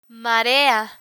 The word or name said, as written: marea